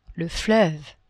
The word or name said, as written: fleuve